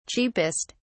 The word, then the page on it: cheapest: British English